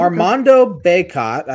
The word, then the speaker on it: Armando Bacot: Armando Bacot, I think that's how you pronounce his name. – Sounds right.